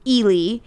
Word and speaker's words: Ely: Ely, E-L-Y. – Sure. – I looked up the pronunciation. Eli. I think it's Ely, like L-E-E, not E-L-I. – Okay. – But it's spelled E-L-Y.